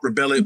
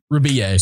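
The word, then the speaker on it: Rebillet: It looks like Mark Ribelli, but it's like Mark Rebier. – Rebier.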